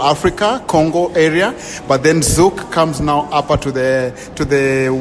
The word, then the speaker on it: Zouk: Yes, and we call it Zouk music. Z-O-U-K or K-E for those who spell it differently.